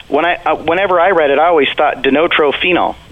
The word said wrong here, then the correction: dinitrophenol: It's dinitrophenol.